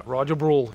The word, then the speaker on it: Roger Brul: Did I say that correctly? – That is correct. Yeah, Roger Brul. You can pretty much say it any way you want, because originally I'm from Switzerland, but my parents are Dutch. So wherever you go, they pronounce it a little bit different.